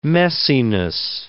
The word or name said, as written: messiness